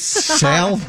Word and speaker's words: salmon: It's Case no, it's pronounced salmon, not salmon. – Is it salmon or salmon? – No, it's salmon.